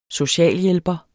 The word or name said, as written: socialhjælper